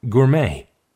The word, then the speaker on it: gourmet: gourmet, gourmet. – 미식가. 구어메, 미식가가 되겠는데요. 프랑스 말에서 왔기 때문에 맨 마지막에 T는 읽지 않고 그냥 구어메라고 읽습니다.